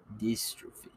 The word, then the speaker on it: dystrophin: Dystrophin.